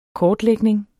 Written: kortlægning

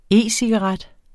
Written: e-cigaret

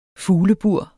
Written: fuglebur

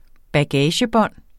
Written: bagagebånd

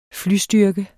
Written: FLYSTYRKE

